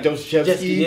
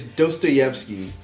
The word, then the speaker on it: Dostoevsky: Even though I love Black Thought, but he mispronounces his name. I thought it was funny. I think he called like Dostoevsky. It's Dostoevsky.